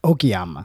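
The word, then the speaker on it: Okayama: So those shows were Akiyama and Nara. – Okiyama. – I mean, that's how you say it.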